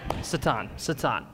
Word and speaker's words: Satan: You can always spot a seminarian because they're going to pronounce Satan with the Hebrew pronunciation. I love that. That was awesome. That was good. Or that too. Satan.